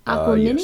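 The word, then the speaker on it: Aquemini: Number two, Outkast, Aquamini. Mm-hmm. Did I say that right? – Aquamini? Yes. I have a hard time pronouncing their stuff, so Young Statman will correct us if we're wrong on this.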